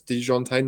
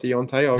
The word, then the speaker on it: DeJounte: And it's DeJounte, not Deontay. I got pulled up on that, Josh. So someone was very unhappy with me for how I pronounced Murray's name.